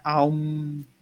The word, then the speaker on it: om: El om que se pronuncia aum, aum.